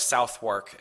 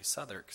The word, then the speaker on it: Southwark: It looks like Southwark, and I called it that, and my dad said, oh, it's actually Southwark.